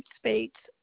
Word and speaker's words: Spates: My last name, Spates, at Yahoo.